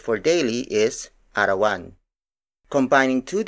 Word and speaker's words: arawan: For example, arawan, arawan, arawan. Arawan is spelled as A R A W A N.